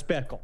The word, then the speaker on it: Bickle: It's that name that rhymes with pickle. It's Travis Bickle.